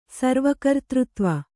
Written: ಸರ್ವಕರ್ತೃತ್ವ

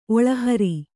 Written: ಒಳಹುರಿ

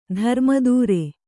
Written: ಧರ್ಮದೂರ

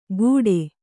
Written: ಕೂಟೆ

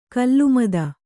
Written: ಖಲಮತ

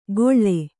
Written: ಗೊಳ್ಳೆ

